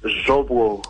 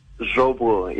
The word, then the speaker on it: Zvobo: You pronounce it like a Russian, actually, so that's really good. – How is it supposed to be pronounced? – Zvobo. – Zvobo. – Zvobo, yes.